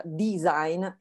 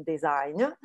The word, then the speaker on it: design: Ecco, non siamo quelli che fanno i disegnini perché design, e si pronuncia design e non design,